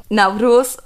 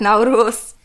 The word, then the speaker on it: Nauruz: Genau, es geht ja heute um Naurus oder Nevrus. Es gibt verschiedene Aussprachen. Ich sag Naurus, Naurus ist tatsächlich das älteste Fest, was auf der Welt gefeiert wird.